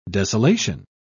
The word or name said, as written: desolation